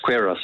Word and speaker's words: Queiros: It's Queiros, yes.